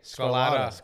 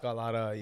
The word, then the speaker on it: Scarlotta: With an A. Scarlotta. Scarlotta. Yes.